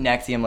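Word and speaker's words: NXIVM: I pronounce it Nexium, like the heartburn medication.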